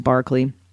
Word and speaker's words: Berkeley: Also, the second argument we refer to is George Berkeley, and it's spelled like Berkeley, but I'm pretty sure because he's English and fancy, it's pronounced Berkeley.